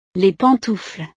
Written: les pantoufles

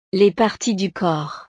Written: les parties du corps

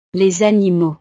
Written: animaux

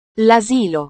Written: lasilo

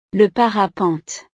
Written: le parapente

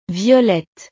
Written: violette

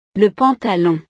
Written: le pantalon

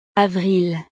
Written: avril

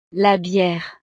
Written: la biere

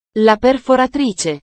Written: la perforatrice

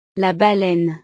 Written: la baleine

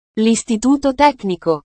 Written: listituto tecnico

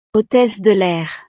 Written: hotesse de lair

